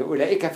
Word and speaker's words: أولئك: طيب تعالوا شوف كلمة زي أولئك هذه الواو لا تنطق واللام بعدها ألف تنطق ولا تكتب. يعني أولئك فيها الظاهرتين حرف ينطق ولا يكتب وحرف يكتب ولا ينطق.